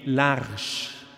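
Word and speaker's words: L'Arche: Vor vielen Jahren, da war ich deutlich jünger, habe ich mal eine Erfahrung gemacht in Kanada, in Toronto, in einer internationalen Kommunität, die LARCH französisch ausgesprochen, heißt die Arche, eine Gemeinschaft von und mit Menschen geistiger und körperlicher Beeinträchtigung und deren Helfern genannt Assistenten.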